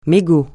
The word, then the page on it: mégot: francais